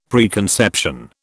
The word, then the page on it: preconception: British English